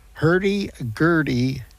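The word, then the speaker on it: Hurdy Gurdy: We're going to hear track 2, Herb's Gurdy. Did I say that right? No, Herdy Gurdy.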